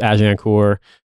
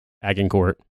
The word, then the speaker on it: Agincourt: Yes, I realize it's pronounced Agincourt. No, I'm not going to go through and correct every single mention of it in this episode. So enjoy Lions Led by Donkeys on the topic of the Battle of Agincourt, or if you're from Detroit, Agincourt.